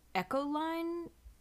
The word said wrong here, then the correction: echelon: swimming. I don't think I'm saying that correctly. It's spelled E-C-H-E-L-O-N. How do you pronounce that? – I would say echelon.